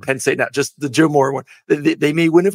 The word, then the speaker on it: Joe Moore: And watch out, they're going to win the Joe, I'm going to say it right, Joe Moorhead Award. Joe Moore Award. – No, Joe Moore. Just, not Joe Moore. Joe Moorhead is the failed Mississippi State head coach.